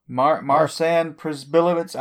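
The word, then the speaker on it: Marcin Przybyłowicz: I'm going to say it's Marcin Przybylowicz or something like that. – What? – That's pretty close. – I got to read this. – Marcin Przybylowicz, I don't know.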